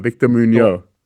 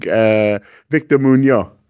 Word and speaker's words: Muñoz: Victor Muñoz. – See, a little lesson. So the N that has got a line at the top across the letter, right? That is, the sound is ñe, ñe, ñe.